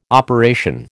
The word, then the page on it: operation: American English